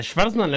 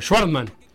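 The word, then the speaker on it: Schwartzman: A Schwarzman le, has dicho de cuatro o maneras diferentes. ¿Cómo querés decirle? Porque a mí no me gusta esa pronunciación forzada que tenés vos. Pero sí si es Schwarz. Sí, pero estamos en Uruguay. Camiseta celeste, short negro, medias negras, Felipe. Bueno, Schwarzman. – Y ponele una U entonces.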